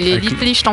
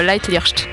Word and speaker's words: Leitlicht: C'est Litlicht en français, mais exactement, c'est Leitlicht, si on veut prononcer avec l'accent.